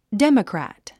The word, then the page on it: democrat: déməkræ`t; デモクラァット